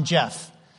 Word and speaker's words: Jeff: Then I went to Mongolia, and the Mullahs remember, it's good to see you again, that they couldn't pronounce the F on Jeff. So they'd say Jep, Jeep. They couldn't get it right at all.